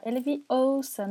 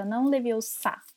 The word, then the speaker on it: Leviosa: fala é Leviosa, não Leviosa. E no livro, o que pega é o Vingardium, né, que ela fala o gar é mais comprido,